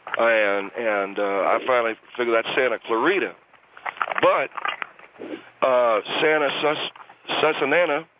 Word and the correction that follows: Santa Susana: And they said it's Santa Susana,